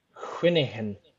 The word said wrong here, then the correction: cwningen: It's Kuningen.